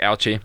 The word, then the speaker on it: Ouchi: Nah, it's Ouchi. – It's Ouchi. It's O-U-C-H-I, so...